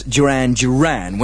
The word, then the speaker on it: Duran Duran: Thank you very much for a couple of people who rang up, and I didn't realise that I said it, but I mispronounced the name. I said Darren Darren and Planet Earth at number 26, when of course it should be, as everybody knows, Duran Duran. When you're doing a tight scheduled programme like this, sometimes you mispronounce things. I do apologise. None of us are too big to apologise. Sorry about that. Duran Duran, of course.